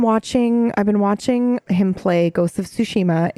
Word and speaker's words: Tsushima: I heard an interesting comment on Ghost of Tsushima, and I don't even know if I'm saying it right. – You are.